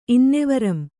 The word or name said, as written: ಇನ್ನೆವರಂ